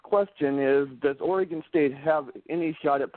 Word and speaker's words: Oregon: By the way, it's pronounced Oregon.